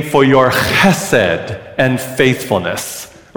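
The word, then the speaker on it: chesed (חֶסֶד): So the first syllable is in the back of your throat. Go, a little more. Oh, good. And then second syllable is sed. So go, chesed. Ready? Chesed. One more time. Chesed. Good. Chesed. One more time. Chesed. It's a little deeper.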